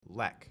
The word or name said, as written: LEC